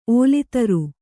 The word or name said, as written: ಒಲ್ತರ್